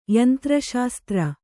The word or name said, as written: ಯಂತ್ರಶಾಸ್ತ್ರ